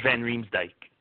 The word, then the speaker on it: Van Riemsdyk: Is it Van Riemsdyk? – Van Riemsdyk. – Van Riemsdyk, thank you.